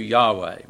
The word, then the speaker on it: Yahweh: Jehovah was I think later on we figured out Jehovah is not the proper way to pronounce the name of God. It really should be pronounced, we hope, we think, closer to Yahweh.